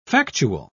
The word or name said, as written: factual